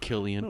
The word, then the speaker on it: Cillian: Pronounced Killian. No, come on.